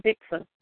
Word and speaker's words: Dickson: Well, let me spell it. D-I-C-K-S-O-N, Dixon.